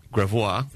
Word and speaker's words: Gravois: Gravois, as Waze pronounce it. Turn right on Gravois Avenue. – Beautiful. – Exactly. Very French.